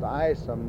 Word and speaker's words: Ison: Following this, we drove down to the Lion Fork Creek in Letcher County to visit the home site and the graveyard of the Isom family, I-S-O-N, pronounced Isom by most people, where we saw the graves of Stuart's great-great-great-grandfather and grandmother, Gideon and Rachel Isom.